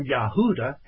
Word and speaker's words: Yehuda: The house of Judah, or Yehuda, as it is properly pronounced in the Hebrew,